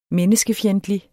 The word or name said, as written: menneskefjendtlig